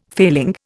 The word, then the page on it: failing: Indian